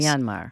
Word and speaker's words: Myanmar: They spelled it B-U-R-M-A, you know, the British pronunciation, Bama. – Okay. – Myanmar is so often mispronounced by English speakers. It's a very difficult word to say. It's actually just two syllables in Burmese. – Myanmar.